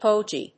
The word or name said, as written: pogey